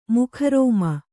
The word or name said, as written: ಮುಖರೋಮ